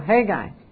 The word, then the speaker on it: Hegai: Now the young woman pleased, you guys say his name, Hegai? Hegai? There you go, Hegai.